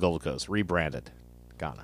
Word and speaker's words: Ghana: Uh, is it uh, Guinea? – Ghana. – Ghana, Ghana, Ghana. I mispronounced it. Yes. Ghana. Guinea is French Guinea. Yes. – Right? Yes. Yeah. Ghana, not Guinea.